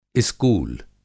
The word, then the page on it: इस्कूल: Hindi